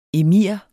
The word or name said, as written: emir